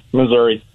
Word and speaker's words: Missouri: Is it Missouri or Missouri? I I still say Missouri. I'm, I'm that's how I learned it, and so I, I pronounce the I, Missouri.